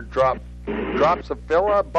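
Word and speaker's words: Drosophila: I'm going to butcher this name. You have to help me out here. Dropsophila bug? – Yep. – Did I say that right? – You said it right.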